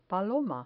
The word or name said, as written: paloma